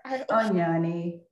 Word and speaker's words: Onyoni: Onyani.